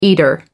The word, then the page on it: eater: American English